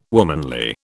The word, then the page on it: womanly: British English